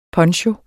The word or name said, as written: PONCHOERNES